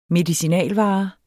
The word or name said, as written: medicinalvare